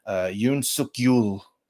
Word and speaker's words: Yoon Suk-yeol: Yoon Suk-yeol. Is that how you say his name? Sorry. – That sounds right.